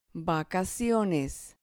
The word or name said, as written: vacaciones